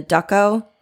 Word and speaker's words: Dachau: But the Luftwaffe conducted around 360 to 400 experiments on prisoners in the Dachau, did I say that right? – Yeah.